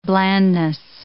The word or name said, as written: blandness